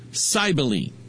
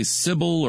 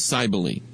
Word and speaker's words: Cybele: Cybele. Some would say Sybil or whatever, but the pronunciation given by Wikipedia was Cybele.